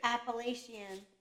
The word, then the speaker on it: Appalachian: It's pronounced McAfee's and it's pronounced Appalachian. Anyone who says it wrong is a heretic. – Appalachian.